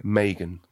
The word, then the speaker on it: Meaghan: Megan. – Like Megan. – Like Reagan. I say it wrong all the time. My kids say it wrong Megan. – All the time. Megan, like Reagan. Megan. M-E-A-G-H-A-N.